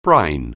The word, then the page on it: brine: anglais